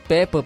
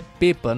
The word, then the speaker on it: Pepa: Mas falando do Cruzeiro, o Cruzeiro tem um acerto verbal com o português Peppa para substituir Pezolano. Então, é, o, o, no caso é Pepa, né? O, a pronúncia do nome dele, Pepa, de 42 anos, é o, é o favorito para substituir Pesolano, Paulo Pesolano, no comando técnico do Cruzeiro.